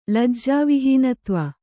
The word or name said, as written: ಲಜ್ಜಾವಿಹೀನತ್ವ